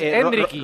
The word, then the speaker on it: Endrick: Es Endricky. Rodrigo ha dicho Endricky con acento en la primera E.